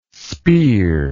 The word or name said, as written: speer